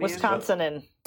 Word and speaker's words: Wisconsinan: Yeah, the Wisconsinonian. How do you say Wisconsinonian? – Wisconsinan. – That's the last Wisconsinian. – Wisconsinan. Yeah.